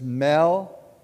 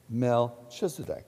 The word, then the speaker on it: Melchizedek: To say it properly, you put the accent on Mel, not Kiz or Chiz or Dek. It's Melchizedek. Melchizedek.